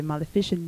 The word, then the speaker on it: Maleficent: This song that you were listening to right now, it was Lana Del Rey's version for the Maleficent movie. That's how you pronounce it.